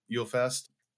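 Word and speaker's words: Julefest: They spell it with a J, but it's pronounced Yule. Mm-hmm. Yule Fest.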